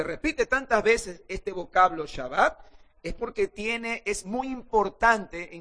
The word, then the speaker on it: Shabbat: No, se pronuncia Shabbat.